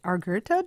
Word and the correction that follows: Agurto: Do I have that right? – Agurto, yeah.